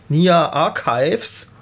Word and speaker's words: Nia Archives: Ja, das war Vertical von Nia Archives. Habe ich das richtig ausgesprochen? – Hast du fein ausgesprochen.